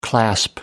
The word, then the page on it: clasp: American English